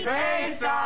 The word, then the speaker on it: Pesach: Happy Pesach! – So, do we say that right? – Beautiful.